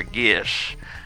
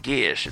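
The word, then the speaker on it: geas: Uh, seven Geases, I love, or Gish, as some people say. It's it's it's pronounced Gish. It's like uh, Sean Connery saying Gears, Gish.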